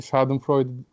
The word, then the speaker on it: Schadenfreude: Bir tanesi Schadenfreude Al- Almanca bir kelime.